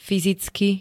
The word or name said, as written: fyzicky